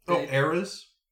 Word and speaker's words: Heirs: H-E-I-R-S. – Mm-hmm. Heirs? – Like Heirs. – Spelled like Ireland? Like E-I-R? – No, like Heirs to the Throne. – Oh, H-E-I-R. – Yeah, yeah. H-E-I-R-S.